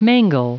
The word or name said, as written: mangle